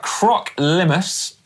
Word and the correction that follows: Croc Limace: Do you think that's how you pronounce it? – Limace. – No, Croc Limace.